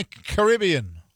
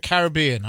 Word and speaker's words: Caribbean: Our illegal strikes in the Caribbean, or the Caribbean. I don't know which pronunciation you prefer, but I'm told both are acceptable in America.